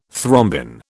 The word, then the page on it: thrombin: British English